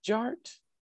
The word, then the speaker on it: Jart: And my name is Jared, aka DJ Art, with two T's for a double set, tink tink the D is silent, so it's just Jart.